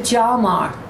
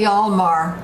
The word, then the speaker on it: Hjalmar: I would pronounce it Hjalmar. – I would say Hjalmar. – Yeah. Hjalmar.